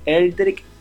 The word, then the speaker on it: Eldrick: Así bajo el nombre de Eldrick Music. Eldrick se escribe E-L-D-R-I-C-K. E-L-D-R-I-C-K Music.